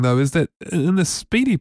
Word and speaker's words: SPDY: It's pronounced Speedy, I think, S-P-D-Y, and it's kind of like an enhanced version of HTTP.